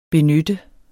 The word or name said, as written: benytte